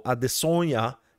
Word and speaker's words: Adesanya: He is now fighting the 20-0 phenom middleweight champion Israel Adesanya, or Adesanya, as his native pronunciation.